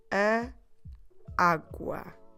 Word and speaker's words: água: G-U-A. That's it. So the first A has an accent to the right, and that is why we're stressing the beginning of the word, água.